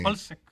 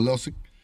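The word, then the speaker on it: Hložek: Holsek. – Holsek, pero, no sé cómo se pronuncia. – Lleva la H y la L, entonces sí. En Losek, yo lo conozco como Losek.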